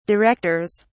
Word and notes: directors: British English

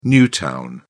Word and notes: new town: British English

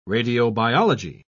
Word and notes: radiobiology: British English

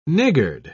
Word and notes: niggard: British English

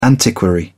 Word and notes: antiquary: British English

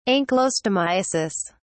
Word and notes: ankylostomiasis: British English

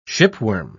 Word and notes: shipworm: British English

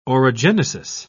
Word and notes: orogenesis: British English

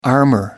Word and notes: armor: American English

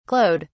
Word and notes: glowed: British English

